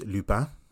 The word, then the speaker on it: Lupin: Well, if it's the French, it's Lupin.